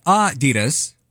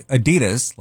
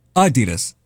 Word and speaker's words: Adidas: Americans mispronounce Adidas. It's supposed to be Adidas, with a slight emphasis on the first syllable, not the second. We always say Adidas, like the D is where we put the emphasis on, but it's Adidas.